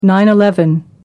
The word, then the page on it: 9-11: American English